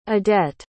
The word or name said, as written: adette